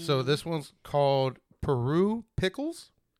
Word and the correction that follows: Puro Pickles: Did I say that right? – No, it's Puro Pickles.